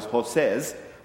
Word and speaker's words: Joses: It was Joses, J-O-S-E-S, which at first I thought it was Joses, but apparently that's not the right way to pronounce. It's Joses, but they changed his name to Barnabas.